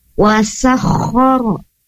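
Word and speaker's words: Wasakhara (وَسَخَّرَ): Wasakhara lakumul laila. Nah, di sana sin tidak ditukar dengan huruf shin. Wasakhara, tapi wasa.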